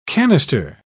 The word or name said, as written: canister